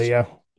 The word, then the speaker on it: Ayla: Ayla, yeah.